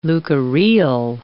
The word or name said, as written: leukorrheal